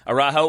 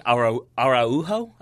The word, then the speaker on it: Araujo: I think it's Araujo if it's Mexican. If it's Colombian, then it's Araujo.